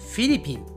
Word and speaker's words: フィリピン: Republic of the Philippines はい、えー、この国の名前ね今私は気がついたのですがなぜか複数形なんですよねフィリピンズになっていますね最後に S がついていますが日本人はこの S を発音しません日本人の言い方ですとフィリピンフィリピンはい、えー、このフィリピンの方もね、日本にたくさん住んでいますね。